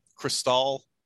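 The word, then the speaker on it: Chrystal: I think it's called Crystal, but it's spelled like with an H, CHRYSCAL, with Lisa Blount and Billy Bob Thornton.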